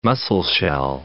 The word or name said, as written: Musselshell